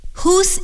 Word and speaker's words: whose: Whose eraser is this? Hoop. A hoop of joy. Whole. The whole world. 今天我们一起看了字母组合 WH 在一起的时候的两种读音。你还记得他们是什么吗？对了，他们是呜和呵。